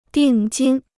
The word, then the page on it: 定金: dìng jīn